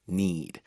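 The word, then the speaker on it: knead: 아, need. 스펠링이 K-N-E-A-D였었잖아요. – Right, as we need dough when we bake, for example.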